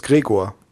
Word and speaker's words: Gregor: No, I know how to pronounce his name in German. It's just Gregor. – Oh. – And that's how I introduced him to also on the show. I just said it the German way.